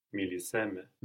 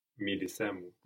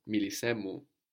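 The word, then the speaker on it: Milisemu (μίλησέ μου): Miliseme. – Very nice with the accent, but it's not so much me, no? Speak to me. – Milisemu. – Milisemu.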